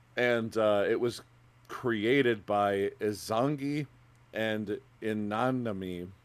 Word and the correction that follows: Izanami: I, I, I totally said that wrong. Izanami, um, the god, uh, Shinto god and goddess.